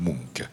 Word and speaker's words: Munch: Ça s'écrit M-U-N-C-H et je crois qu'on prononce Munk.